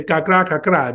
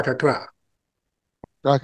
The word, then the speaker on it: kakra kakra: At the Ashanti tree language, they say kakra kakra. It means little by little. Kakra kakra. – Kakra kakra.